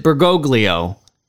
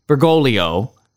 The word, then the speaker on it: Bergoglio: By the way, Father Altman pronounces it Bergoglio. In Italian, that second G would be silent, so it is Bergoglio.